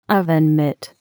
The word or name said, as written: oven mitt